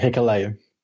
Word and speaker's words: Hikuleo: And we move into the second match, which was Kota Ibushi and Juice Robinson against Takahashi and Hikuleo. Is that how you pronounce his name? I've only seen him a couple of times. – Yeah, Hikuleo.